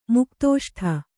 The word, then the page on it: ಮುಕ್ತೋಷ್ಠೆ: muktōṣṭhe